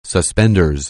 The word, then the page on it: suspenders: anglais